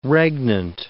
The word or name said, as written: regnant